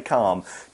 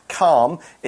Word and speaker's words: calm: To explain what we mean here, let's take a closer look at the adjective calm, C-A-L-M. Calm, it's a kind of a silent L. It's not calm or anything like that. It's calm.